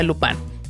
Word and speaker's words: Lupin: Si usted es fan del anime, le debe de sonar el nombre, tal vez Lupin o Lupin, porque el nombre se escribe Lupin, pero al ser francesa se pronuncia Lupin.